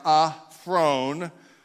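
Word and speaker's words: aphron (ἄφρων): Now, when we break this word down, aphron, we find that there actually are two words here that are melded together. The last syllable in this word, phron, like throne, but with a PH, phron.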